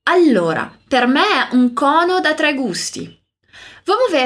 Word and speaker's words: cono: O som é bem aberto, então nada de cono, mas cono. Diz aí, cono.